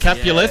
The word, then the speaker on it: Capulet: Capulet.